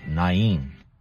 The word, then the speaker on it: Nain: Or Nain, as many people in English pronounce it. For those of us who speak Portuguese, to say Nain sounds strange. But I checked, and in Hebrew, to the best of my knowledge, they pronounce it Na-in.